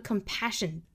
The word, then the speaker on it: compassion: 最后的 compassion, 注意这两个 s 的读音应该是一个轻辅音的 sh, 大家注意 sh 的发音是要卷舌的 sh,